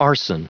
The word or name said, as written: arson